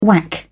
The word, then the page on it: whack: British English